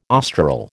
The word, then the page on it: austral: American English